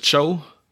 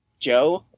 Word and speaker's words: Cho: We say name was Cho. – Cho. – It was just Cho. Okay. – That's how you pronounce it.